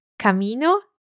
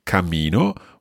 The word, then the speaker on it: camino: Camino, cammino. – So I hope you can hear the difference there. Camino, one M, cammino. With two M's and a little bounce in the middle.